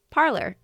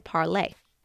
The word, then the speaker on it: Parler: Parler means to talk in French, and is meant to be pronounced as parler. But as more people started saying the app's name, like the English word parler, that pronunciation took over.